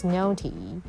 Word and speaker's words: naughty: Naughty, naughty 是指很顽皮的，很调皮的。She was naughty. 那这个 naughty 要注意呢，n 发 n 的音，au 发 o 的音。GH 不发音，然后 TY 发 T 的音，所以 She was naughty, 很调皮。She liked to do something naughty every day.